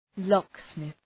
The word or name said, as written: locksmith